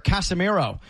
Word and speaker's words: Casemiro: In fact, there is a player, Casemiro, who is going to play. – That's four. That's four syllables.